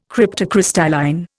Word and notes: cryptocrystalline: Indian

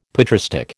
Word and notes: patristic: American English

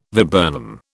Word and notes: viburnum: British English

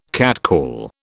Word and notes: catcall: British English

